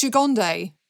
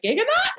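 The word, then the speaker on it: Gigandet: Apparently, I believe it's Gigonde. – Okay, Gigonde makes more sense because I was always like, Giganot? Cam Giganot, who I've interviewed a few times.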